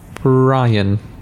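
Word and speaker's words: Bryan: Bryn Gunn or Brian Gunn? – Brian. – But spelt with a Y.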